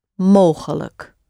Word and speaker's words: mogelijk: M-O-G-E-L and the A-I-J-K. Mogelijk. Mogelijk. The luck sound at the end, L-I-J-K, at the end of words is always pronounced luck.